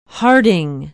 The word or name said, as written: harding